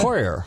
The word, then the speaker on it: Hauer: And Hauer, how do you say that again? Hoyer. Hoyer. Hoyer. Uh, That's a German name.